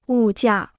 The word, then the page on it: 物价: wù jià